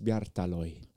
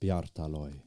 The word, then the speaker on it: Bjartalid: Johannes Bjartaloi. – Johannes Bjartaloi. – Det er sånn det uttales.